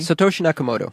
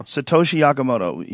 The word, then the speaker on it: Satoshi Nakamoto: Satoshi Nakamoto. – Satoshi Nakamoto.